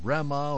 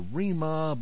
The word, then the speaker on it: Rema: He is a singer-rapper from Nigeria, and his name is spelled R-E-M-A, so people are saying Rema or Rima, but I'm told the actual pronunciation of his name is Rema, so we want to make sure that we get it right.